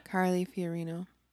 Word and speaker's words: Carly Fiorina: Carly Fiorina.